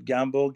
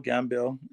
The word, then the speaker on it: Gambill: People pronounce it Gambill, Gambill. – Okay. – It's the same.